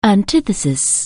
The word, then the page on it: antitank: anglais